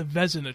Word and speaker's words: Vezina: No, just tell me how to say it. – It's the Vezina Trophy.